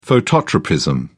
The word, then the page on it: phototropism: British English